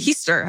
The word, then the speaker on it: Heaster: Way back in October of 1896, my great, great, great question mark Aunt Zona Heaster, pronounced like Easter with an H, mm. Heaster.